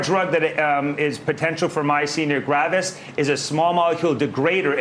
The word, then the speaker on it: Myasthenia gravis: Myasthenia gravis? – Yes.